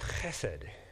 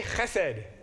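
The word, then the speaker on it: chesed (חסד): The Hebrew word is pronounced chesed. Chesed. We should all learn that word. It's a very, very powerful word, like the English word love. So let's try it. Ready? Chesed. That was actually quite good. The guttural is hard, right? Chesed. Not chesed, chesed. And the accent is on the first syllable, chesed.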